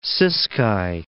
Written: Ciskei

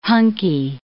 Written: hunky

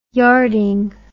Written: yarding

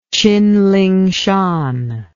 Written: tsinlingshan

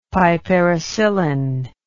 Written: piperacillin

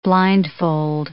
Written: blindfold

